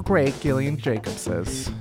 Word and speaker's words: Gillian: Gillian, like GIF. Honestly, her name, I think, is vindication for me and all the other heroes who pronounce it GIF with the hard G, not JIF, like the peanut butter.